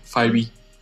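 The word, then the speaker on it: Fivio Foreign: Después pasó también esto de que le dispararon a Fibio Foreign, creo que, creo que se dice así, no sé si es Fibio o Fibio, pero es uno que era amigo de Pop Smoke. – Sí, Fibio.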